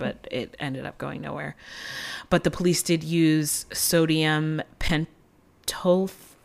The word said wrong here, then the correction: sodium pentothal: I don't know if I'm saying that right. I don't know what that is. It's truth serum. Am I saying that wrong? – Pentothal. – Sorry, sodium pentothal.